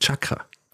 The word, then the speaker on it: Chakra: Und äh, sage Ashoka-Chakra. Stimmt nicht. Das heißt Chakra. Ashoka-Chakra. Ja, das sind äh, traditionellerweise meine Aussprachefehler, die ich jetzt hiermit korrigiert habe.